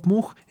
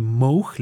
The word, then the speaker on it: Moog: se encuentra Robert Moog y que Shirley Moog le dijo, no se dice Moog, se dice Moog.